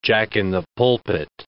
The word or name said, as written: jack-in-the-pulpit